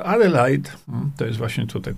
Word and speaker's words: Adelaide: Mm, y, Adelaide, y, tak to się wymawia, Adelaide,